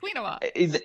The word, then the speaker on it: quinoa: Quinoa.